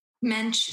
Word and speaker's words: Moench: Mench, silent O. It's kind of a curveball.